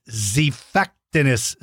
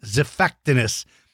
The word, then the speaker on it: Xiphactinus: It's called Xiphactinus, but its name is spelled with the letter X. The X sounds like a Z. There, you made an X with your hands, with your arms. Good job. So the X sound is pronounced like the letter Z.